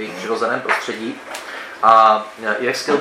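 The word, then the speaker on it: Uexküll: píše se to hrozně to jméno, ale vyslovuje se to Uexkill,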